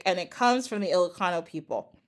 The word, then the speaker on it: Ilocano: So your mother's people are the Ilocano. Am I saying that right? – Yes. – Ilocano.